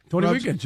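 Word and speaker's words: Tony Reagins: We will N-O-T, not interrupt. Next hour, Tony Regans. Did I say that right? – Tony Regans, yeah.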